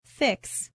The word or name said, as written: fix